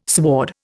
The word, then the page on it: sward: Indian